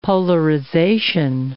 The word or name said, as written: polarization